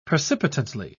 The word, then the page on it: precipitantly: British English